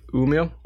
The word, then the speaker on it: Umeå: Yeah, I say Umeå.